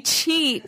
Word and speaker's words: cheat: Although we have a shit day, not shit, but cheat, shit. I'm European. I don't know how to pronounce it. You pronounce it. So you have a meal where you can go high. – Oh, you're trying to say cheat.